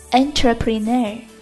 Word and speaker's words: entrepreneur: 最后要说到的单词是 entrepreneur E-N-T-R-E PRENEUR 企业家，这是个很常用的词汇，和企业 enterprise 很相像，但是发音相差的却很远。